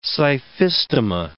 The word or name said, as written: scyphistoma